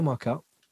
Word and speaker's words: Marcquelle: So he came over talking to me like, hey, Marcquelle, how are you pronouncing my name right straight away? Most people say it wrong, yeah, right?